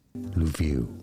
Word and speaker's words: Lviv: Lviv. And now you know, like and subscribe if you found this video useful. Is how you say it correctly.